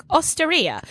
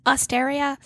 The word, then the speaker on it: Osteria: Oh, no, I'm sure that's not how you pronounce it. I'm sure it's like Osteria. Osteria. Is that Osteria?